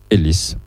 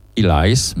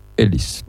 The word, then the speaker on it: Ellis: Robert Ellis, Ellis c'est un prénom et un nom de famille courant au Pays de Galles, il se prononce toujours Ellis, pas Ellis, mais Ellis.